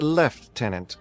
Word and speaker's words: Lieutenant: That's pronounced Left-Tenant, good sir.